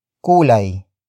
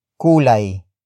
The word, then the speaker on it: kulay: Word for word it's ano-ano, ang, kulay, ng, bahaghari, ano-ano, ang, kulay, ng, bahaghari, ano-ano, ang, kulay, ng, bahaghari.